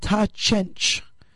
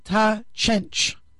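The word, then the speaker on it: Ta' Ċenċ: which is called Ta Chench. And it's spelled T-A, and then a second word, C-E-N-C, but the C's are spelled, are pronounced like C-H. So it's Ta Chench, C-E-N-C.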